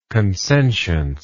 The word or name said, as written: consentience